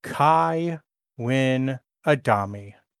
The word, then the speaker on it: Kai Winn Adami: She is Kai Winn Adami. – The villain we love to hate. – You said it correctly, child.